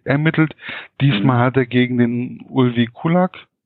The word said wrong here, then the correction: Kulac: Kulak? Kulac. Mhm. Kulac, gut, ich, wie das ausgesprochen wird, hat er ja in der Richtung ermittelt.